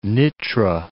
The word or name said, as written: Nitra